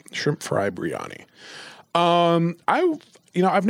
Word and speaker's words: biryani: The shrimp biryani? – Biryani. Yeah. Biryani, like brie, but ani.